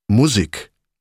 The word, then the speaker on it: Musik: Musik, mit Betonung auf der ersten Silbe, also ist, wenn die Marching Band kommt.